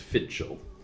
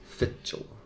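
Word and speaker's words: Fidchell: So the anglicized way of pronouncing it is Fitchel. – Fitchel.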